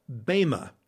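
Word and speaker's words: bema (βῆμα): In fact, when you see the word judgment seat in your English Bible, that is a single Greek word that is pronounced bema.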